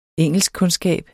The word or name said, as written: engelskkundskab